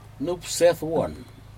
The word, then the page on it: nupsedwun: Aneityum